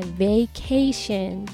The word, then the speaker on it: vacation: vacation っていうのが本来の発音です強調するとなので vacation じゃなくて vacation vacation でちょっと「え」の音に近くなるかなっていう発音になります